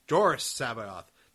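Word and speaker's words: Jorus C'baoth: It's like, the original Sabaoth was, his first name was pronounced Doris Sabaoth. This one's pronounced Daruus Sabaoth.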